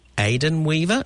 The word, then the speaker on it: Aidan Weaver: So now we welcome on the line Yvette Carroll, who is has been very busy writing the chronicles of, and I think it's Aidan Weaver. Is that how you say it, Yvette? – Yes, it is.